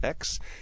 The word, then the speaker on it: Aix: Many people have probably seen that rosé called AIX. It's pronounced X.